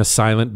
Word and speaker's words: SLNT: Do you have your phone or your laptop in a silent bag when you're not on it? These are the SLNT. It's pronounced silent.